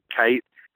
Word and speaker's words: Kyte: I mean, really, my last name is spelled K-Y-T-E. If you Google Greg Kite, I'm the only guy.